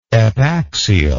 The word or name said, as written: epaxial